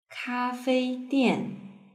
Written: kafeidian

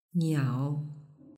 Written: niao

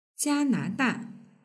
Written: jianada

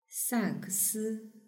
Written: sakesi

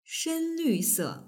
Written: shenlvse

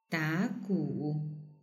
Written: dagu

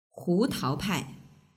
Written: hutaopai

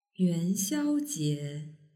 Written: yuanxiaojie

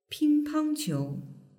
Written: pingpangqiu

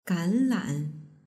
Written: ganlan

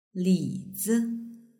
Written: lizi

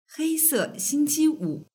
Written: heisexingqiwu

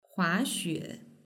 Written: huaxue

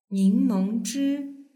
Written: ningmengzhi

